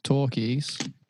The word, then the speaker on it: Takis: So that thing, Takis, is pronounced Torquis,